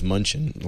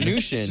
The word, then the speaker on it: Mnuchin: And now that Stephen Mnuchin, I mispronounced his Munchin last week. – Or Munchkin. – Mnuchin. – Just don't call him Munchkin.